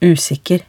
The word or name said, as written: usikker